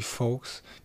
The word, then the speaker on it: Foux: ist von Lancy Fox. Wie wird er ausgesprochen, Lancy? In dem Lied wird der Lancy V ausgesprochen, aber F-O-U-X also UK-Rapper.